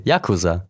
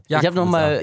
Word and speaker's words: Yakuza: Yakuza. Yakuza. Ich habe einen Podcast angehört und ich habe mir eine Aussprache nochmal von Google geben lassen. Alle sind sich einig, Yakuza.